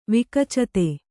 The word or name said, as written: ವಿಕಚತೆ